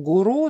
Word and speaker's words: гуру: Слово гуру, кстати, которое очень привычно для нас, в идеальном таком варианте произносить надо с ударением на второй слог «гуру», но в практике, на самом деле, этот вариант менее употребим, поэтому вариант «гуру» он тоже допустим.